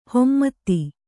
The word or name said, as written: ಹಿಮ್ಮತು